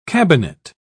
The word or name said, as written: cabinet